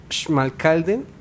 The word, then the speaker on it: Schmalkalden: La S al principio siempre va a sonar Ch. – Entonces, sería Schmalkalden. – Perfecto.